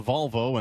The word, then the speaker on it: Volvo: Did I mispronounce two brand names? – You did. – There? – It's a Volvo and not a body part, and it's an Audi.